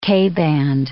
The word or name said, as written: K-band